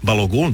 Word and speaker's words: Balogun: Da ukratko prepričamo, američki reprezentativac Folarin Balogun, ako se to tako izgovara. – Balogun, da.